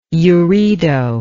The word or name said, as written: uredo